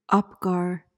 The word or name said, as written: apgar